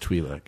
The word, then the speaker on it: Twi'lek: And it's pronounced Twi'lek.